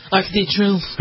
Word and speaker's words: Arc de Triomphe: Arc de Triomphe.